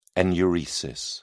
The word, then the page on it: enuresis: British English